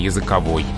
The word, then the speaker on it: языковой: языковой. Я вот, скажем, возьмем меня, я языковый невежда или языковой? Языковой. Языковой невежда.